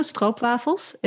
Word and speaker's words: stroopwafel: I would say, do you know stroopwafels? It's pronounced stroopwafel in English, in American English.